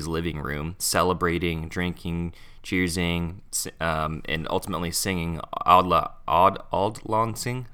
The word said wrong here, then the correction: Auld Lang Syne: How do you pronounce that? – Auld Lang Syne. – Auld Lang Syne together.